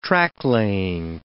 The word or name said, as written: tracklaying